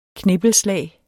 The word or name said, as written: knippelslag